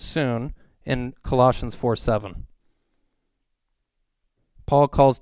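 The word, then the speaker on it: soon (σύν): And it's pronounced soon. S-U-N in English. The transliteration, soon.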